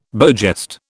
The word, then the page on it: beau geste: British English